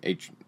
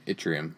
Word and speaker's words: yttrium: Yttrium. Yttrium. – Yeah. I believe is how it's pronounced.